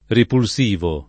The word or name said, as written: ripulsivo